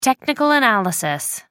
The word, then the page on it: technical analysis: American English